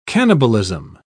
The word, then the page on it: cannibalism: British English